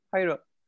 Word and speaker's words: Jairo: Jairo. Like with kind of similar to an H? – Yeah, like Jose is with...